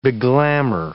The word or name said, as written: beglamour